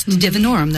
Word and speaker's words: Salvia divinorum: Is it Salvia divinorum? – Yes. – Did I get the name right? – That's divinorum. That's it.